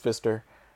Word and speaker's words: Pfister: By the way, side note, unfortunately his name is Pfister. – Pfister? Okay. – Wally Pfister. Well, it's Pfister. Pfister. If you want to pronounce the P. – Pfister. – But I feel like the P is probably silent.